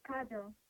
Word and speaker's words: Kadoon: My name is Kada and I'm calling from Yaya. – Your name is Kada? – Yes, Kado. – Kado, could you spell that for me? – Yes. K-A-D-O-O-N.